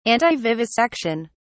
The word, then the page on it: antivivisection: British English